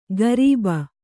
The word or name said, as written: ಗರೀಬ